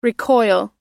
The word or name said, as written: recoil